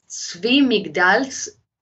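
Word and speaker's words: Zwi Migdal: The name is Zvi Migdals. It's spelled Z-W-I. The second word is M-I-G-D, as in David A-L.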